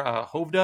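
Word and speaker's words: Hovde: And it appears that, you know, the Republican there, Hovda, I think his name is pronounced, is running ads attacking Baldwin for he would say he's not attacking her for being gay.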